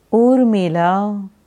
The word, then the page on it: Urmila: Sanskrit